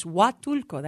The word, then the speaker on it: Huatulco: Huatulco? – That is very close. Huatulco, that H is silent.